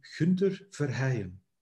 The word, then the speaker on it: Gunther Verheyen: So, but if I would pronounce my name the way we say it here in the northern part of Belgium, it would be Gunther Verheyen.